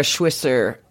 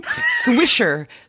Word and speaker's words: Swisher: On the Pivot podcast, Kara Swisser, sorry, I said her name wrong. Kara Swisher, I can't say her name. Swisher.